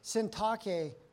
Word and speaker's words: Syntyche: I plead with Euodia, and I plead with Syntyche.